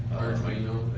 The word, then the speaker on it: Arduino: it's pronounced Quadrino. – Arduino, whatever that's called.